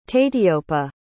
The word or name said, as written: tateeyopa